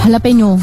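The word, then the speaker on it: jalapeño: Iya, jalapeno. – Kok tulisannya kan jala? – Kan kalau orang Meksiko, orang Latin ngomongnya gitu. J jadi H. – Jalapeno.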